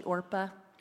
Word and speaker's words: Orpah: FYI, I don't know if y'all know, but Oprah is actually Orpah.